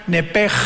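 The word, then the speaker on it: nepech (נפש): Los que quieran apuntarlo, la palabra alma en el hebreo es nepesh, con SH al final, como suena nepesh. Pero la SH en hebreo se pronuncia como J, entonces se pronuncia nepech, como el francés, verá que es gutural, nepech.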